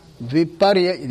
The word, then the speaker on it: viparyaya: Then the next one is viparyaya. Okay. Viparyaya. Yeah, let's pronounce it viparyaya.